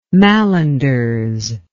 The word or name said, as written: malanders